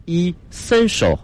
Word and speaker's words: essential: essential，它的发音是 essential，后面是一个翘舌音 essential。